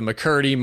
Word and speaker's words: McCurdy: But so I went to PT today, and last July, a bunch of people on IG were doing like the McCurdy mile. I'm probably pronouncing that wrong. I've excelled. – No, you're right. – Okay, awesome. – Yeah, you're good.